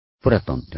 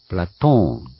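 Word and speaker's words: Platon: プラトンではなく、プラトーンなんですね。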